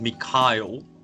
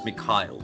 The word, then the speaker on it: Mikhail: It is Mikhail, so Michael, but the Russian spelling and pronunciation. So it's Mikhail.